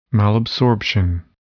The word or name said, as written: malabsorption